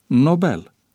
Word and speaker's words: Nobel: Deci pronunția corectă este Nobel, cu accentul pe cea de a doua silabă.